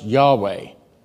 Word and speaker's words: Yahweh: But here's what most Bible scholars think. They think it's pronounced Yahweh. There's no Jewish person who will ever say the name of God. They'll never say Yahweh.